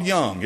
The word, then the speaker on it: Jung: It wasn't that long ago that one within a stone's throw of us was having meetings talking about Carl Jung, J-U-N-G. Do you know who he was? Carl Jung. His name's pronounced Jung. It's J-U-N-G.